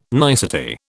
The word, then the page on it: nicety: British English